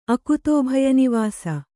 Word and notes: ಅಕುತೋಭಯನಿವಾಸ: Kannada

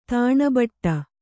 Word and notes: ತಣ್ಬಿಟ್ಟು: taṇpiṭṭu

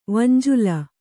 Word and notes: ವಂಜುಲ: Kannada

